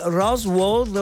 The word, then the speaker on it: Rosewood: Dopo tanti depistaggi, l'hanno pubblicato il Rosewood Hotel, si pronuncia così.